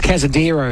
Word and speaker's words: Cazendero: It was Cazendero at 12.30 and 2.30. I butchered that. It's Cazendero, I should say, the 7 at Casino paying on 5, 4, 3 and 1.